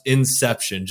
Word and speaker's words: INXEPTION: And by the way, for anyone listening, INXEPTION has an X in it. It's pronounced INXEPTION, just so you don't have to get corrected like I did originally.